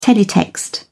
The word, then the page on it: teletext: British English